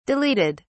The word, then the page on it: deleted: British English